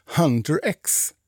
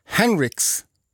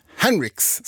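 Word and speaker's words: Huntr/x: Jag sa ju att det här var Golden med Hunter X. Nej, så ska man inte uttala det där namnet, har jag lärt mig nu. Utan det här är ju, det ska vara Henrix ska det uttalas.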